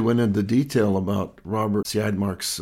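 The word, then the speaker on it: Siodmak: It was directed by Robert Siadmak.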